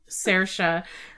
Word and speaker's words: Saoirse: And I'd also like to point out that with 18, Maven, I can never pronounce this. – Saoirse.